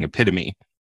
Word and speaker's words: epitome: But of course he was saying epitome.